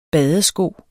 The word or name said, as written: badesko